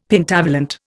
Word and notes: Pentavalent: Indian

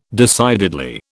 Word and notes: decidedly: British English

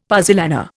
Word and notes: puzzolana: Indian

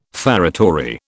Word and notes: feretory: British English

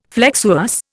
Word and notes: flexuous: Indian

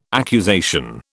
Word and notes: accusation: British English

